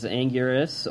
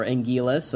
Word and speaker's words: Anguirus: Because of that, he can also fight another monster, which is Anguirus, or Anguilus. I still go with that old-school American Anguilus pronunciation, where he was played again by Tezuka.